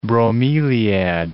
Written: bromeliad

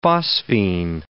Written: phosphene

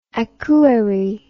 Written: Akure

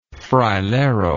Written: frailero

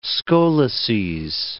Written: scolices

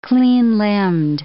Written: clean-limbed

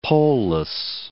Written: poleless

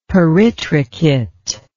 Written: peritrichate

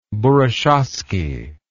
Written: burushaski

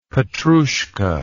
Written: Petrouchka

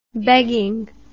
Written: begging

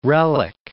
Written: relic

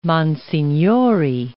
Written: monsignori

